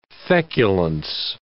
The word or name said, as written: feculence